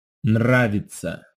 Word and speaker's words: Нравится: You have an N and a rolled R. That's very hard to say slowly. Listen again and also note how the emphasis is on the first syllable. – Нравится.